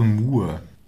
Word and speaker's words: Moe: Ich glaube Moore. Also M-O-E geschrieben, weil das O wird wie U ungefähr ausgesprochen, habe ich gelesen, und es ist wohl getrennt, also Moore.